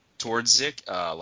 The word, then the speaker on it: Twardzik: Twardzik, like his player archetype is gone.